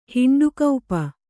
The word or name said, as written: ಹಿಂಡುಕೌಪ